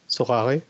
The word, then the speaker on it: Sorare: Et euh, là, il fera un partenariat avec euh, le Sorare, Sorare, apparemment ça se prononce.